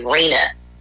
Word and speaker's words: RHNA: But it's RHNA, and the acronym is RENA.